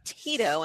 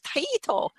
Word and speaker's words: Taito: Because I thought we had pronounced it Taito. And then I heard people saying Tito, and I'm like, oh, well, maybe it's Tato. So I changed my pronunciation. And then there was that whole thing. And I'm like, oh, no, I'm just going to go with Taito.